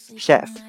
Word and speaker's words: chef: 那么在这里呢，提醒大家一下啊，这个单词不读作 chef 啊，虽然写成 ch，但是呢发成 sh sh 的音啊，叫做 chef。